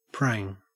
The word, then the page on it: prang: Australian English